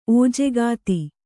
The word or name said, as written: ಓಜೆಗಾತಿ